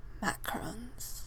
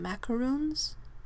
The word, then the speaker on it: macaron: See, I feel like this word is controversial because some people pronounce them macarons in the States and some people pronounce them macaroons. But I'm of the opinion that macaroons is actually a coconut flavored, like a coconut chocolate pastry, whereas a macaron is that fancy one that's made with almond flour and often deflates.